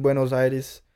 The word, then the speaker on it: Buenos Aires: But yeah, no, he was just you probably read it, but he was trying to have a dinner in Buenos Aires, in Argentina.